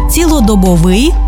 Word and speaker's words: цілодобовий: Отже, цілодобовий та щодобовий, 100%-овий, ринковий, одноразовий та багаторазовий, наскрізний та вишиваний.